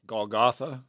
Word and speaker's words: Golgotha: And, of course, one is Gothica. Did I say that right? – Golgotha. – Well, all right, I was close.